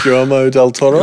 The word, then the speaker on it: Guillermo del Toro: I can't even pronounce it. Guillermo del Toro. I'm such a white person. Guillermo. – Guillermo del Toro.